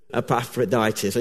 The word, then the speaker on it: Epaphroditus: But one of the, one of the things he particularly thanks them for, I'm going to see if I can pronounce this guy, Ephroditus. I think that's how you pronounce his name. Is that right? Epaphroditus.